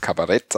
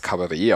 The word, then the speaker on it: Kabarett: Und zwar in Deutschland wird es eher als Kabarett ausgesprochen, ähm, so wie es auch geschrieben wird. Ähm, in Österreich und der Schweiz ähm, spricht man das Kabarett aus, wie es äh, aus dem französischen Ursprung ähm, kommt.